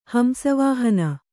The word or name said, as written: ಹಂಸವಾಹನ